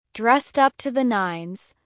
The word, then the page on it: dressed up to the nines: American English